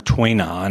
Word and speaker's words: Tui Na: And for anybody who cares about that, that is spelled T-U-I-N-A. And it is pronounced Tui Na.